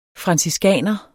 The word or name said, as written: franciskaner